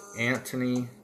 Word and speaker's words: Antony: And then we come to Antony Blinken. It's like Anthony without the H. So I wasn't mispronouncing it. It's spelled A-N-T-O-N-Y-B-L-I-N-K-E-N.